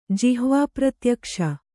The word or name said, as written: ಜಿಹ್ವಾಪ್ರತ್ಯಕ್ಷ